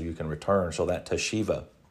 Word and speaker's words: teshuva: The Hebrew word for that is teshuva. It's pronounced teshuva, so it's teshuva.